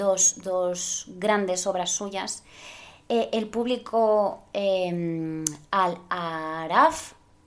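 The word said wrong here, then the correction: Al Aaraaf: o sea, no, lo pronuncio mal, es que es Araf, con dos as y con dos as también, al Araf.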